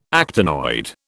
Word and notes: actinoid: British English

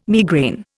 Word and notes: migraine: Indian

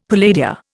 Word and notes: palladia: Indian

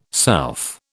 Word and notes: south: British English

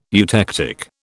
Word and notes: eutectic: British English

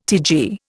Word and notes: TG: Indian

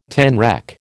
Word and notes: tanrec: American English

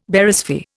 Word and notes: Barysphere: Indian

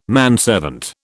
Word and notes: manservant: British English

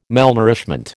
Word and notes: malnourishment: American English

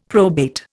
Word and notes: probate: Indian

